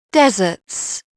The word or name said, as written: deserts